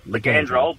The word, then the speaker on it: Ligandrol: Can you just tell us the pronunciation of that drug one more time? – Well, Ligandrol, but if you really want to be fancy, just call it LGD4033, just to complicate it.